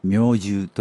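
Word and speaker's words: 命終: NHK ポッドキャストこんばんは作家の高橋源一郎です明獣という言葉があります命に終わると書いて明獣と読みます意味は命尽きること今日この後紹介する作家のコ春人さんはその最晩年に明獣三部作と呼ばれる作品を書きました。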